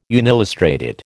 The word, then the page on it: unillustrated: American English